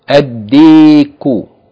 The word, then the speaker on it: ad-diku: The next word, ad-diku, ad-diku, ad-diku, meaning the rooster. Again, this word begins with del. Del is a sun letter. That means it has to be doubled, and the lam should not be pronounced.